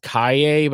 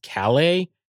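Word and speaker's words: Calle: I thought it was pronounced Kaye, but I think it's pronounced Calais.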